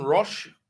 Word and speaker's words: Roche: Well, Simon Roche, R-O-C-H-E. It's a French surname.